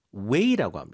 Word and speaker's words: whey: 이걸 영어로는 whey라고 합니다. 스펠링이 yeah. 어떻게 되나요? – W-H-E-Y. 근데 this way, that way. 이쪽이요, 저쪽이요 할 때 W-A-Y랑 발음이 똑같아요. – 발음은 똑같아요. – 네. – 그렇지만 스펠링은 W-H-E-Y.